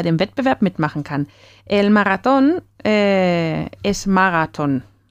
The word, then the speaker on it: Marathon: que se escribe a la antigua forma con el TH y se dice a ver cómo se dice en alemán. Maratón. Sí, con la A larga, ¿no?